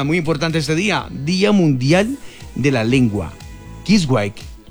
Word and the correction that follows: Kiswahili: Bueno, creo que así se pronuncia. Kiswahili, así día de la lengua Kiswahili.